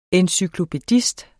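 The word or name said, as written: ENCYKLOPÆDIST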